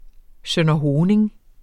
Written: sønderhoning

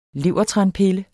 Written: levertranpille

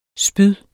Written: spyd

